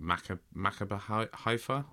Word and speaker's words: Maccabi Haifa: We've got Chelsea coming up, and then we got, I'm not really sure how to pronounce it, but Maccabi Haifa. – Haifa, yeah.